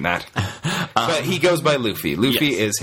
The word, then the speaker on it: Luffy: Luffy.